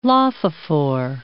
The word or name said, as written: lophophore